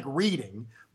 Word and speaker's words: Reading: It's spelled like reading, but it's the Reading Terminal Market.